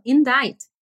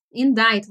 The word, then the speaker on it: indict: E tudo bem, a pronúncia, tranquilo, indict, indict, você acha que é uma palavra tranquila, até você ler ela. Indict se escreve I-N-D-I-C-T, não tem nada a ver a escrita com a pronúncia, nada, nada, nada a ver.